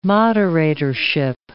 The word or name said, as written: moderatorship